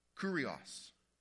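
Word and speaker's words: kurios (κύριος): It's a Greek word that is pronounced kurios. Kurios, which means master.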